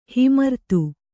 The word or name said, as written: ಹಿಮರ್ತು